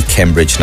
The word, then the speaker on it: Cambridge: Ni Cambridge Naranjo.